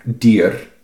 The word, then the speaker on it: dyr: But yeah, my favorite one so far is that dyr, with D-Y-R, which is animal, you say... – Dyr. – And... That pronouncing the letter R as r, or r, skarr, heter det.